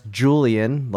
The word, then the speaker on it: Julian: Tim, that's pronounced Julian, like Julian Alaphilippe.